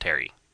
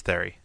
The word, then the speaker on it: Thierry: I think you just pronounced it Terry. – I thought it was Thierry. – Yeah. I think it's the French spelling of Terry, pretty much.